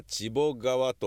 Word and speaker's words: 血流川: 血流川ではなく読み方は千ボ川と読むらしく、